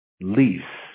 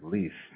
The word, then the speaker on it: Lies: And that's your last name, L-I-E-S, right? – Yeah, it's pronounced Lease. – Lease. I was going to ask. Yeah, I want to make sure I got the pronunciation correct. Lease.